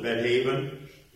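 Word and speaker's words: Beth Aven: Hosea, who ministered after Elisha, called this city Bethaven, is the way I would pronounce it, Bethaven, meaning house of wickedness.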